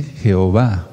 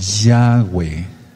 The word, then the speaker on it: Yahweh: Aquí no dice Jehová, no, pues Jehová quiere decir el destructor. Aquí no dice eso. Aquí dice Yahweh, así se pronuncia.